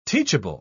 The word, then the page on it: teachable: British English